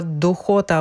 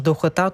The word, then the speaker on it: духота: Українською скажемо задуха, духота або ж духота, тут подвійний наголос, подуха, але це вже діалектне значення.